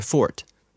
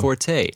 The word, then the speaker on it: forte: It's not my fort. That's the official way I'm supposed to pronounce it. But people, everyone says forte.